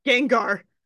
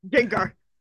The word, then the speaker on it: Gengar: it's a Gengar. I'm like, oh, I'm sorry. Have I been pronouncing Gengar wrong? Gengar. – She just went German like it's Gengar.